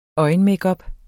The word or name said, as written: øjenmakeup